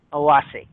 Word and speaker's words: Awassi: In English, it is spelled A-W-A-S-S-I, Awasi.